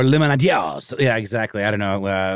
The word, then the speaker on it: limonadiers: But yeah, lemonaders would probably be the Americanized pronunciation, and lemonadiers, because it's spelled L-I-M-O-N-A-D-I-E-R-S. Sounds like a little more of the Francophone pronunciation.